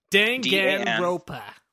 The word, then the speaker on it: Danganronpa: Danganronpa.